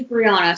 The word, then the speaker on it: Briana: It is Briana, so that's good.